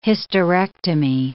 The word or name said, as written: hysterectomy